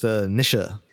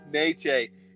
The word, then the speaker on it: Nietzsche: For the record, it's pronounced Nisha. – That's really good. Nietzsche. It's nature. Nietzsche.